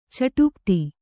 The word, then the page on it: ಚಿಟುಕೊತ್ತು: kannada; ciṭukottu